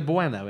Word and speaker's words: Bueno: Brueno. – Brueno. – Brueno. – It's not got an R in it. Has it? – Buet. How do you say it then? – It's Kinder Bueno, isn't it? – Kinder Bueno.